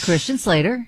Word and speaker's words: Christian Slater: Christian Slater? – Yes, Christian Slater.